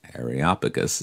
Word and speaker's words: Areopagus: And then notice in verse number 19, and they took him and brought him unto Areopagus,